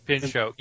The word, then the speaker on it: Pinchot: What exactly is Bronson Pinchot doing? Did I say it right? Did I screw up Pinchot. Something else's name? – Pinchot.